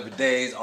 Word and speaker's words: bidet: Like, I pronounce it like boudet, but just like French. That's how I pronounce it like boudet. – Boudet. – Boudet, but it's actually bidet. Bidet. But I'm Geechee, so I say boudet. – How you spell it, motherfucker? – B-I-D-E-T.